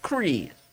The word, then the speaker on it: Kreese: Yeah, it's got to be Kreese. – Like four E's. – The only person has the definitive. – No, a little lisp at the end. – Come on, say it. – Kreese. – That's it. That's it. There you go. That's how you say it.